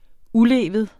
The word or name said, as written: ulevet